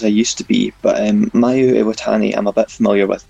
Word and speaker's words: Mayu Iwatani: And I apologise dreadfully if I butcher his name, Mayu Iwatani. Is that how you pronounce it? – Yeah, spot on.